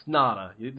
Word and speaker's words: Ngata: It's Haloti Ngata. It's no, it's not. It's Ngata. The G's silent, buddy.